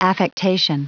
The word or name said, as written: affectation